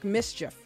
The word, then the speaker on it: MSCHF: so many points okay so he has teamed up with um, a collective an art collective um, I don't know if the art collective is called Mischief or if it's just MSCHF. That's the way that it's spelled MSCHF. It looks to me like Mischief, which I think is probably what they're going for. So I'll just, I'll oblige Mischief. He's